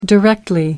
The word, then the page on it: directly: American English